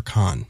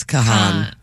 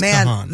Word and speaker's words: Kahan: Is it Noah Kahan or Khan? – It's Kahan.